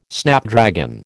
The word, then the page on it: Snapdragon: American English